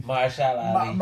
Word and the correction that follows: Mahershala Ali: Mahershala Ali.